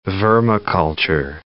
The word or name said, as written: vermiculture